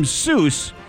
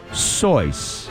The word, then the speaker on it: Seuss: If you're calling him Seuss, he pronounces it Soyce.